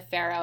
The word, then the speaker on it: Farough: And I am at Amanda Farrow.